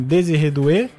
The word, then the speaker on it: Désiré Doué: O ataque titular, Mbappé, Olise e Dembélé, se um deles estiver mal ainda tem Désiré Doué, jogou muito pelo PSG na temporada passada, e ainda Barcola também.